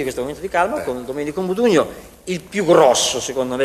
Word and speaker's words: Domenico Modugno: È Domenico Modugno, giuro.